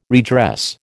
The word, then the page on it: redress: American English